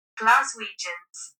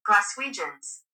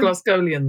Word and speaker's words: Glaswegians: How do I play it? – Glaswegians. – There you go. Do you want to hear the American pronunciation? – Go on. – Glaswegians. – Did you get that? – Yeah, it's quite similar. – Yeah. – It's not Glasgowian then.